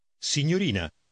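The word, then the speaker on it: signorina: Повторяйте за диктором по частям. – Рина. Рина. Ньо. Ньо. Ньорина. Ньорина. Синь. Синь. Синьорина. Синьорина. – Скажите, синьорина. – Синьорина.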